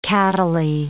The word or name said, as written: cattily